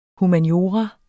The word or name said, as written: humaniora